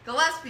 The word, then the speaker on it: Gillespie: Gillespie.